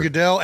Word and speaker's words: Goodell: You know, Roger Goodell. I mean, are we saying this right now? Are we? – Yeah. – Really, Roger Goodell absolutely killed.